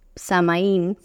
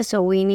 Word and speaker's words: Samhain: Y Sowin, que se escribe totalmente distinto, se escribe como Samaín, si alguien lo leyera. Cuando yo lo leía originalmente pensé que era así. Se pronuncia Sowin y como mencionaba, es una tradición celta.